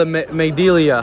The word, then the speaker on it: amygdala: Okay, so the amygdala, how do you say it? – Amygdala.